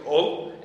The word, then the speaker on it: Hull: And then there's the context of Hull, and specifically Hull, that's how they would say it. You don't need to pronounce the H, just Hull will do.